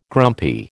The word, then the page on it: grumpy: American English